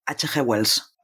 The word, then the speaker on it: H.G. Wells: H.G. Wells.